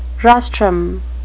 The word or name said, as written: rostrum